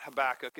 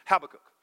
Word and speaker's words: Habakkuk: Well, they always accent the Hebrew. It's on the back syllable. It's not Habakkuk, it's Habakkuk. And so Amittai, you know, it puts the emphasis there.